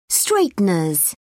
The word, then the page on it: straighteners: British English